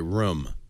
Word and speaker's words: room: Finally, so somebody who says room right, by the way, room. That is the right. – I blame it on my Canadian heritage. – That is the right way to say it.